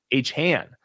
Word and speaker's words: Achane: So I'm just trying to figure out what percentage of this backfield, Achan, how are you pronouncing it again? – It's like Achan.